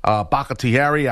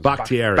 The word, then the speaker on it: Bakhtiari: That's a stout line with Billy Turner there, Beluga, Bakhtiari. I always say, David Bobbins. How do you say his name? – Bakhtiari. – Bakhtiari. I always screw his name up.